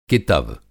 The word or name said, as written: कितव